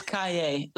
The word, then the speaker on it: Kaye: It's Kaye, like Yippee-Ki-Yay, motherfucker.